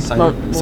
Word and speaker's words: Seider: Seider.